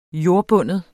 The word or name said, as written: JORDBUNDNE